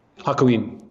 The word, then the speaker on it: Hakuin: there's one person that really drew my attention, and correct me if I say this incorrectly, but it's at Hakuin. Hakuin. – Yes. Yeah.